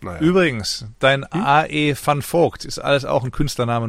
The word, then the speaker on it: Van Vogt: war Alfred Elton van Vogt. Aber er war kein Holländer. Er war Kanadier und Amerikaner auch noch obendrein. Mhm. Und Van Vogt spricht man es aus in den USA.